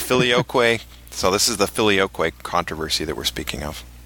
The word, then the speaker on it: filioque: And when we're speaking of this controversy, it's referred to, isn't that the filioque or filioque? How do you pronounce that? – Filioque. – Filioque? – Uh-huh.